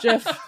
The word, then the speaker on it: GIF: It is a soft G pronounced GIF in the story.